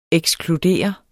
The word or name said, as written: ekskludere